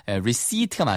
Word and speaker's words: receipt: 영수증은요, receipt인데 p 발음이 묵음이니까 receipt가 맞습니다.